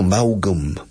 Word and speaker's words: Maugham: Escrito Maugham, Maugham, como Vaughan. Vaughan se pronuncia en inglés Vaughan. Y Maugham, escrito igual, pero con M a cada lado, a cada extremo, es Maugham. Vaughan Maugham, Somerset Maugham.